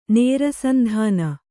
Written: ನೇರಸಂಧಾನ

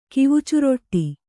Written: ಕಿವುಚುರೊಟ್ಟಿ

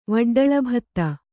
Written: ವಡ್ಡಳಭತ್ತ

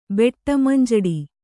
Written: ಬೆಟ್ಟಮಂಜಡಿ

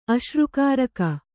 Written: ಅಶ್ರುಕಾರಕ

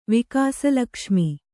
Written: ವಿಕಾಸಲಕ್ಷ್ಮಿ

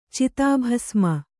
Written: ಚಿತಾಭಸ್ಮ

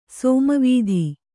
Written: ಸಾಮವೇದ

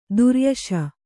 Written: ದುರುಯಶ

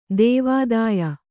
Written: ದೇವಾದಾಯ

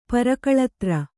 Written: ಪರಕಳತ್ರ

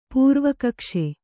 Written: ಪೂರ್ವಕಕ್ಷೆ

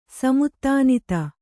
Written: ಸಮುತ್ತಾನಿತ

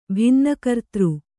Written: ಭಿನ್ನಕರ್ತೃ

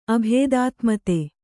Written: ಅಭೇದಾತ್ಮತೆ

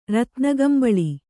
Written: ರತ್ನಕಂಬಳಿ

